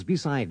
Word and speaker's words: beside: Virgo's beside Leo. In these dialogues, remember to pronounce the first E of below, beneath, beside, and between with the sound of the I in sit.